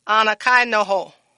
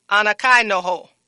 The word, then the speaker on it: anakainoo: So, it is spelled A-N-A-K-A-I-N-O-O. Again, that's spelled A-N-A-K-A-I-N-O-O. And it's pronounced Anakai noho. Anakai noho.